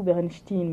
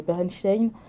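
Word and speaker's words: Berenstain: Parce que, en, fait, les Berenstein, Berenstein la plupart des Américains euh, pensent que ça, se lit Bernstein avec E-I-N, oui. alors que c'est Bernstein avec un A. Alors pour nous, Français, ça ne change pas grand-chose, mais pour eux, ça change toute la prononciation et ça a bercé leur enfance. Donc euh, vraiment, quand les gens apprennent que c'est pas du tout Bernstein, mais Berenstein,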